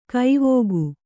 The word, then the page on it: ಕವಿಕೆ: kavike